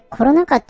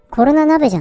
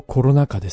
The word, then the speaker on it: コロナ禍: それコロナ禍って読むんだ。コロナ鍋じゃないんだね。 – 一応コロナ禍ですね。